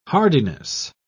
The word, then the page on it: hardiness: British English